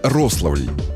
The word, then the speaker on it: Рославль: Один из них – Рославль в Смоленской области. Да, именно так, на первый слог.